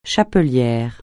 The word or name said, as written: chapelière